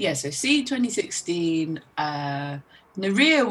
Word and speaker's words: Nérija: And you're also in Nérija. I, I hope I'm saying that right. – Yep, you. Yep, that's correct.